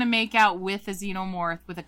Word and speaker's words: xenomorph: Would you rather make out with a xenomorph? That's how you say it, right? – Yeah, a xenomorph.